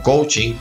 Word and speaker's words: coaching: La palabra coach, pronunciada así coach, es el profesional que utiliza el coaching, pronunciado como coaching, el coach, dos coach.